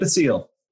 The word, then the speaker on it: Basile: Nick Basile.